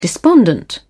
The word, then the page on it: despondent: British English